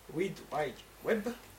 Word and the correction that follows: Read Write Web: Facile à dire. J'ai mal prononcé. – Pas compris. – Mais... Je vais faire en français cette fois-ci. Read Write Web.